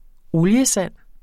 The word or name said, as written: oliesand